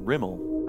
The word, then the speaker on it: Rimel: That was the opening of The Disinterment by Dwayne Rimmel. I hope I'm pronouncing that right. Is that right? – Yeah.